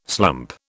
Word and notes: slump: British English

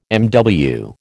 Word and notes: MW: American English

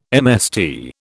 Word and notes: MST: British English

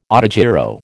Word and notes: autogyro: American English

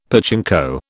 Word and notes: pachinko: British English

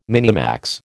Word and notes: minimax: American English

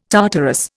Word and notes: Tartarus: Indian

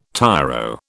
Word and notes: tiro: British English

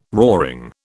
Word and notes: roaring: British English

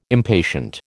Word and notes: impatient: American English

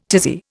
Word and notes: tizzy: Indian

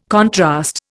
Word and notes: contrast: Indian

